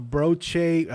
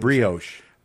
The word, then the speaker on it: brioche: Havarti cheese, jalapenos, fried onions, and a broche. I can even brioche.